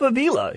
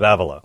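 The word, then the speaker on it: Ávila: And I said, Teresa of Avila. He said, no, Teresa of Avila. Oh, okay. All right. That's how it's pronounced. I've only read it. So anyways, her name is T-S-H.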